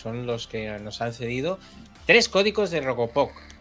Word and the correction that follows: Robocop: Códigos de Robocop.